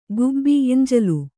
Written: ಖೂಬಿ

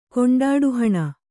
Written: ಕೊಂಡಾಡುಹಣ

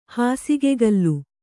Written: ಹಾಸುಗೆಗಲ್ಲು

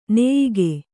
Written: ನೆಯಿಗೆ

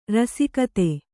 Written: ರಸಿಕತೆ

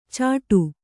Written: ಚಟು

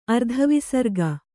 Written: ಅರ್ಧವಿಸರ್ಗ

